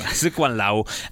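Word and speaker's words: Tsz Kwan Lau: Zikwan Lau.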